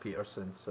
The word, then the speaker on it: Pedersen: I think it's just like Peterson, so with a T, even though it's spelt with a D.